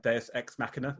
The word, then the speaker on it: Deus Ex Machina: Is it Deus Ex Machina or? – Deus Ex Machina.